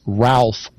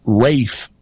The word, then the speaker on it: Ralph: You'd say it as an American. His name is Ralph Rackstraw. But if you watch performances, they use British pronunciation and often call him Rafe Rackstraw.